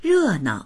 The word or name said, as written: re4nao